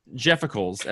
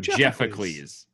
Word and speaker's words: Jeffacles: Jeffacles, as we said the whole time, is supposed to be pronounced like Pericles, the leader of Greek democracy. So Jeffacles.